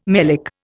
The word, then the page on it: melic: Indian